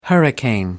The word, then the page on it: hurricane: American English